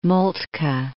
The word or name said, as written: Moltke